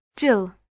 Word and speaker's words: Gill: Yes, it's T-U-R-N... B-A-L-L. – Thank you. And what's your first name? – Jill. That's spelled G-I-L-L.